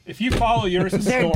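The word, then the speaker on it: Urías: How come I can pronounce it? It's Urias. – I've heard it pronounced like five different ways. – People are saying Urias. – How did Joe Buck pronounce it? – Well, here's how the Spanish broadcast pronounced it. – Julio Urias, Dodgers on 100. – Urias. – U is a U.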